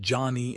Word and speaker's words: Gianni: That extra cash keeps Gianni Infantino, pronounced Johnny Infantino, firmly in power, no matter what happens off the pitch.